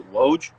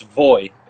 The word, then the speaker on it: Woj: So the reason it's spelled W-O-J, that's why most people pronounce it Woj. But in the Polish language, the W is a V, and the J is a Y, so it's Voj instead of Woj.